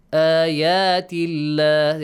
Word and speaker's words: آيات الله: آيات الله. There is a baby alif on the top. آيات الله. Produce the sound of lam in the word Allah with a light sound. Lightly pronounce it.